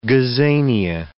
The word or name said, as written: gazania